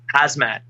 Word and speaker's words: Khamzat: Hazmat.